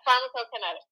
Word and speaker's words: pharmacokinetics: Yeah, pharmacokinetics.